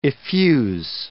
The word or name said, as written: effuse